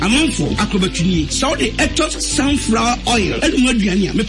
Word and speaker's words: Aetos: Spell it, mommy. – A-E-T-O-S. Etos. – Etos. Correct. Etos sunflower oil.